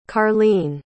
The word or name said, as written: karleen